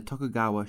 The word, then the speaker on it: Tokugawa: which is when the country was ruled by Tagua. No. – Do you know what? When you're writing these, you should write it phonetically out how you say it. – Was ruled by the Tokugawa shogunate from 1603 to 1868.